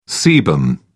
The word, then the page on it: sebum: British English